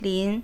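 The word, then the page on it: li2n: tone 2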